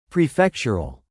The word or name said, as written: prefectural